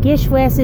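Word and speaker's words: GISHWHES: As I explained in a previous episode, Gish West is the acronym pronounced as a word, short for Greatest International Scavenger Hunt the World Has Ever Seen.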